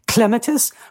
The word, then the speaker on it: clematis: We all say clematis because it just comes off the tongue, but it's properly pronounced clematis from the Greek word klema, to climb.